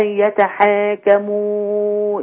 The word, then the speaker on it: يتحاكموا: فين الألف اللي بعد الحاء؟ – أي يتحكموا. – هي عندك كده من غير ألف ولا في ألف؟ – في ألف. – طيب أنطقها إزاي؟ – أي يتحكموا. – ليه يتحك؟ ليه ما يتبقاش يتحا؟ – أي يتحاكمون.